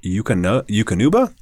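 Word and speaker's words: Eukanuba: The American Kennel Club / I hope I don't mispronounce this, Yukonuba? – Yep. – Thank you.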